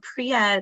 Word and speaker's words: Priia: Yeah, we love a brand called Priya. It's spelled P-R-I-I-A, and it's made by another acne specialist like me.